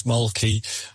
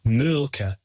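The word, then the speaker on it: Mölkky: We use Malky. Most of the people we play against use Malky, but the Finns have a very unusual pronunciation, which, please forgive me, I'll try, it's Mulka. So I think that's, something a bit more like that. But to us, it's Malky.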